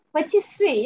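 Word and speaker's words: pâtisserie: bir patisier tam olarak nasıl telaffuz ediliyor bilmiyorum ama oradan geldiğini söyleyebiliriz herhalde. – Patisserie yani Türkçe'ye çevirirken pastane diye çevirmiş sonradan patisserie ama genellikle o pastanelerin çoğunda bir de brasserie yazıyor yani ilanlarda, o da birahane gibi öyle diyebiliriz yani iki işlevi birlikte beraber yerine getirebiliyor.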